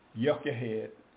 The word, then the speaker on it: Jochebed: Yeah, I'll have to go look, and I don't think I looked up how to actually really pronounce it, but it would have been pronounced with a Y. Jochebed.